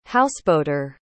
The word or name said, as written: houseboater